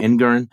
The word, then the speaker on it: Engoron: Uh, the judge Engoron, is that how we pronounce it? – In, mm-hmm. Engoron,